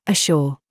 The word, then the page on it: CURE: British English